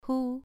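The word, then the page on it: hu: tone 2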